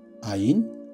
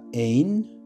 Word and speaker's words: Ayin: Esta palabra en hebreo algunas veces es pronunciada así, Ayin, en otras ocasiones en la Biblia solamente es pronunciada ein o a veces solamente en.